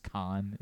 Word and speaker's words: Kahan: Noah Kahan? – Noah Kahan. Yeah. I think it's just Kahn is how he pronounces it.